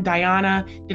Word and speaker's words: Dajana: Welcome, Dajana.